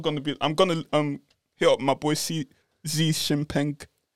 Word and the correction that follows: Xi Jinping: I don't think you're saying that right. – Big up him, man. – Sorry if I got the pronunciation wrong. Well, I guess we're both not allowed in China now. – I've got. – You said his name's Xi Jinping. – Xi Jinping. – Jinping. – Where's XI? How do you pronounce XI? C.